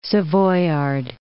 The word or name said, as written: Savoyard